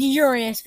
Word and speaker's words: Uranus: It's pronounced Uranus because we don't actually want to target any bad things. Uranus. It's pronounced Uranus.